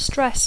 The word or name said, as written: stress